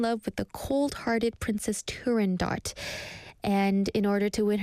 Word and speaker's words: Turandot: Firstly, I'm going to talk about Turandot, the opera. We talked about this on Culture Walk. Turandot was just in Korea recently in the summer, in July, and Turandot, or the Italian pronunciation is Turandot, yeah, is an opera in three acts by Puccini.